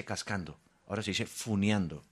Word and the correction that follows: funando: Pero eso es youtuber, un mensaje de 48 años, por favor. – Sí, funando, me aclaran.